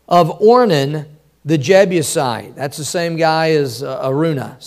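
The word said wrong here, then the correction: Araunah: So just Arona, actually, is how you say it.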